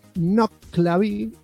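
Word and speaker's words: Nuckelavee: Nuklavi. Pronúnciese Nuklavi, o sea que lo pronuncié mal.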